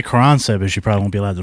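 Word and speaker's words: Caransebeș: If you want to pronounce it correctly, it's Caransebish, I guess. – Caransebish. – But Caransebish is right.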